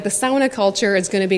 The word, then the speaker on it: sauna: So sauna or sauna is the correct way to say it.